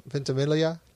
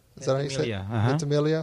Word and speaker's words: Ventimiglia: Milo Ventimiglia. Ventimiglia.